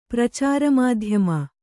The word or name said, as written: ಪ್ರಚಾರಮಾಧ್ಯಮ